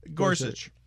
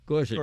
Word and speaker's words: Gorsuch: Daisy says, I would have been inclined to give Gorsuch if they had, is it pronounced Gorsuch? – Gorsuch. – Gorsuch. – Gorsuch, I believe, right? Neil Gorsuch.